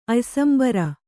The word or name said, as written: ಅಯ್ಸಂಬರ